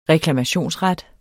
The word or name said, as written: reklamationsret